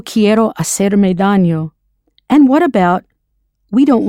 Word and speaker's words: daño: To harm oneself or to get hurt is hacerse daño, a reflexive. Daño means harm or damage and is spelled D-A-N-Y-O. That's an N with that little squiggly on top.